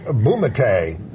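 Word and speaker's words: Bumatay: Patrick Bumate. Is that how we say his name? – Yes.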